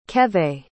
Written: kevay

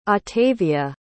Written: ottavia

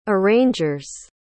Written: arrangers

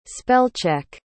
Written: spell-check

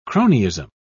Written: cronyism

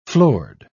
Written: floored

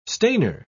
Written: stainer

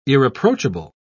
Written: irreproachable

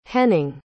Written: Henning

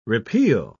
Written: repeal

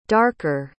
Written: darker

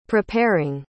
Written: preparing

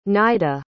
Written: naida

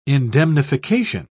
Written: indemnification

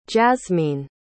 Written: jasmeen

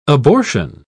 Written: abortion

